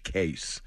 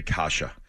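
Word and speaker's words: Kase: And it's pronounced Andre Kasha. And listen, I mean, all kidding aside, you know, you got to get used to some of the pronunciations. And it's, so it's K-A-S-E, Kasha.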